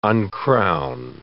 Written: uncrown

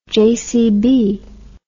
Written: jcb

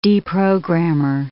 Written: deprogrammer

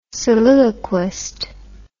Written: soliloquist

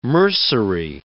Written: mercery

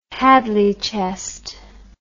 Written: hadley chest

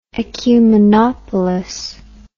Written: ecumenopolis

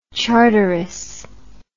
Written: charteris

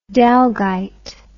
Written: dalgyte

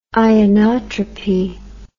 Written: ionotropy

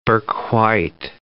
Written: Bourke-White

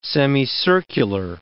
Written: semicircular

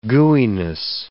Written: gooeyness

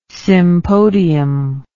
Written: sympodium

